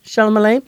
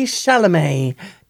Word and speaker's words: Chalamet: 9 out of 10 for Marty Supreme, starring Timothy Chalamet. Did I say it right there? – No. – Ah, what? I was so proud of myself. – Timothy Chalamet.